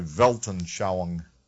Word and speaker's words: Weltanschauung: If any of you are conversant with philosophy, you run into a term called a Weltanschauung.